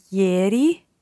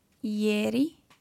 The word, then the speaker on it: ieri: Ieri. – Ieri. – Uh-huh. And then night. – Notte. – Notte. So just watch your vowel sounds in there.